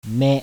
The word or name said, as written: ME